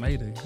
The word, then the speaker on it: Maeta: Is that how you say it? – Maida. Yeah. – Maida! – M-A-E-T-A. – Maida. You know, is it a hyphen E? – No, it's M-A-E-T-A.